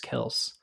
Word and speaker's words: Kelce: It's actually pronounced Travis Kelce.